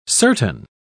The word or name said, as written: certain